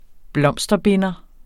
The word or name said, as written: blomsterbinder